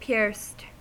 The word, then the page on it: pierced: American English